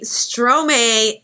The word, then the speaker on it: Stromae: But Stromae is actually a play on words for the word maestro. M-A-E-S-T-R-O. It's just like he flipped the syllables.